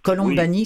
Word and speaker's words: Colombani: Colombani, c'est vraiment comme ça se prononce. C-O-L-O-M-B-A-N-I.